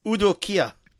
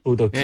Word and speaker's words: Udo Kier: Udo Kier. – Udo Kier, yeah.